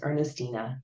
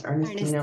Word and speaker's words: Ernestina: It's Ernestina. Yeah, it's Ernestina Ernestina.